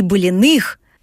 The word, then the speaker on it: Былиных: Ирин, так что же обязательно нужно увидеть из наследия семьи Былиных, правильно поставила ударение, как местный житель, в вашем чудесном городе?